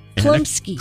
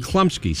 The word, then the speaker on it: Chlumsky: Klumsky. – How do you say it? Klumsky. – Klumsky. Klumsky. Klumsky. Right.